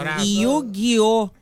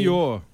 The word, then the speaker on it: Yu-Gi-Oh!: Yu-Gi-Oh! É a pronúncia correta, então, aí. – Yu-Gi-Oh!